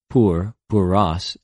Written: πυρ, πυρος

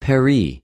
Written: περι

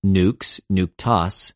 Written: νυξ, νυκτος

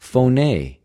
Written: φωνη

